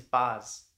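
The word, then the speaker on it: Bazz: Yes, my name is Baz. – Ooh, how would you spell that? Is that uncommon? – It's B-A-Z-Z. – Aww. – All right, Baz. – Okay. So like Buzz, but with an A.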